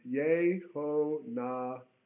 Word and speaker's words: Yehonadab: Now, when he departed from there, he met this interesting character named Yehonadab. That's how it's phonetically pronounced in Hebrew.